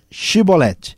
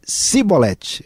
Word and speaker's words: Shibolete: Se respondesse que não, diziam: Então diga Shibolete. Se ele dissesse cibolete, era uma questão linguística, fonética. Sem conseguir pronunciar corretamente a palavra, prendiam-no e matavam-no no lugar de passagem do Jordão.